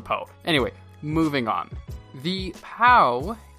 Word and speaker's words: PAO: It is spelled P-A-O, and for the life of me, I want to call it the Nissan PO, but I guess I'm just a Kung Fu Panda fan, because it is pronounced PAO, not PO, and so throughout the episode, I will make sure, or at least try to, call it a PAO.